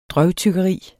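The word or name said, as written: DRØVTYGGERIS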